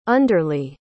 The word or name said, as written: underlye